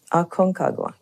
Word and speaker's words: Aconcagua: Aconcagua, right.